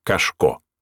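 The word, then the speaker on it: Кошко: Но сразу после, с маленькой черно-белой фотографией на столе, на посетителей взирал начальник московской сыскной полиции Аркадий Францевич Кашко. Ударение на последний слог.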